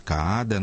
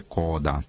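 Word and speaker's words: Kaada: В нашей музыкальной прессе его все время называют Каада. Ну, на самом деле пишется Каада, но поскольку в Норвегии я бывал и с этим самым Джоном знаком, знаю, что произносится это на самом деле Кода.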